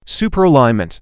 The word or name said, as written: Superalignment